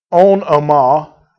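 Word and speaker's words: onomah (ὄνομα): But in this context, and the word that's used here, the word used here is onomah. That's how it's pronounced in the Greek.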